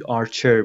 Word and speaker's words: Archer: مثل اینجا مثلا من گفتم آرکر، ولی تو لیسنینگ آرچر بودش.